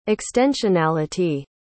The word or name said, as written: extensionality